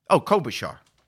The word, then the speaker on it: Klobuchar: Did I mispronounce it? I did. What is it? Klobuchar.